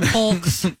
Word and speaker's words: folks: Folks. – What's wrong with that? And yolks. – It's mouthy. – Yeah. – You're mouthy. – Mouth. – Yeah, because you don't pronounce the L.